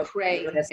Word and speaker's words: Creagh: Spell your last name for everyone, because they might think Creagh, which I'm glad I called and spoke with you ahead so I knew how to pronounce it, but it's spelled C-R-E-A-G-H. – It so is, yeah, Creagh. It's actually pronounced Creagh.